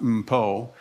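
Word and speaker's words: Mpho: And so I looked down at the footnotes, and I saw that Desmond and his daughter, it's pronounced Mpo, had written a book.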